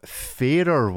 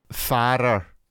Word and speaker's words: Farer: So the dear owner of Fairer Watches reached out and contacted me. It is pronounced fairer. So there you go. – Mm. – Resolved. – Like the fairer sex?